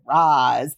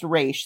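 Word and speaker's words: Ra's: It's supposed to be pronounced Raish.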